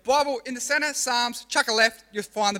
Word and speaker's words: Psalms: So, it's pronounced Psalms, but it's got a silent P at the start.